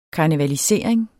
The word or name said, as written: karnevalisering